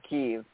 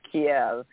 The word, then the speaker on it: Kyiv: And also want to point out that for our American listeners, there's Kyiv and then there's Kiev. And Kiev is the Russian pronunciation. Kyiv is the Ukrainian capital. So don't call Kyiv Kiev. That is not how it is said.